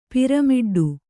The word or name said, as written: ಪಿರಮಿಡ್ಡು